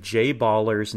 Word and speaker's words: Jay Baller: And this is Harry Carey and Steve Stone on the call, both of them pronouncing Jay Baller's name to rhyme with Valor, Jay Baller.